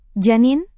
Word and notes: janin: Indonesian

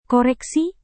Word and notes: koreksi: indonesian

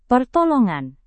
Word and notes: pertolongan: Indonesian

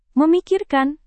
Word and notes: memikirkan: Indonesian

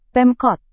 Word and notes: pemkot: Indonesian

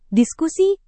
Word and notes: diskusi: indonesian

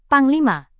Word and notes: panglima: Indonesian

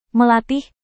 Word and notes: melatih: Indonesian

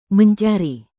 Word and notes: mencari: indonesian

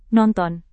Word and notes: nonton: Indonesian

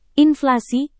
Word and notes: inflasi: indonesian

